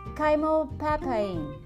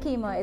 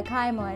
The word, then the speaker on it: chymopapain: I actually looked up the way to pronounce this, so let me say it chymopapain. Okay, I hope I got it right. So it's not chemo, it's uh, chymo, and it's um, written C H Y M O P A P A I N.